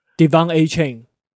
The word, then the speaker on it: De'Von Achane: 怎么念？De'Von Achane。